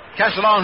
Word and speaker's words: Castiglione: It's spelled C-A-S-T-I-G-L-I-O-N-E. It looks like it's an awful tough one. But the way he says the family pronounce it is Castellone, just like O-C-A-S-A-L-O-A-N, Castellone.